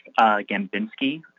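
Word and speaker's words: Gembinski: Yeah, my name is Chris Gambinski, C-H-R-I-S. G-E-M-B-I-N-S-K-I, and I'm a professional treasurer and compliance specialist.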